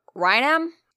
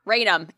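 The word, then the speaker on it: Raynham: It's going to be Rayum. – Rainum. It's fucking Rainum.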